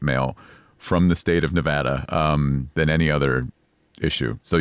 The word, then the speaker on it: Nevada: was the correct way to pronounce the state that Las Vegas is in. – Mm-hmm. – Because a lot of politicians would say Nevada. – Wrong. – And that is wrong. – Wrong. It is Nevada. And I had to train myself to say Nevapple so I could pronounce the A right.